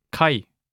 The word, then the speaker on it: Kai: It's like the I in Kaiser, I. An example of this is in the word Kai, which means harbor.